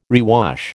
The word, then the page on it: rewash: American English